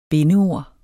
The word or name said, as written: bindeord